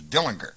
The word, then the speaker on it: Dillinger: It had a hard G. It was a Germanic name. It was Dillinger, And even though the public pronounced it Dillinger, he always pronounced it Dillinger in the entire family, some of whom still live in Mooresville, Indiana, which is a little soft country town south of Chicago.